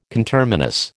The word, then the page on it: conterminous: American English